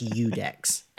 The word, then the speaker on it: IUDEX: It's pronounced Eudex.